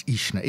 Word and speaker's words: Uisneagh: Ishna, I'm pretty sure it's Ishna, even though it's spelled U-I-S-N-E-A-G-H. Ishna Tracy on bass and Jay Duff on drums.